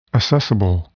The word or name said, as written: assessable